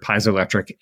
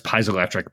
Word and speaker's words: piezoelectric: We call it piezoelectric. – Okay. – I think in America, you're probably right on that.